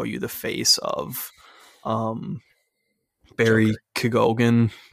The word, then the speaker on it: Keoghan: I think it's Keegan. I've heard it pronounced that way on other podcasts.